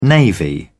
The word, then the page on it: navy: British English